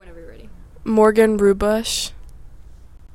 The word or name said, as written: Morgan Ruebusch